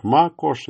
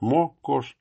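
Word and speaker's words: Макошь: произносима иногда как Ма-кош, иногда как Мо-кош.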